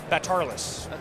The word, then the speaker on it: Batarlis: Batarlis.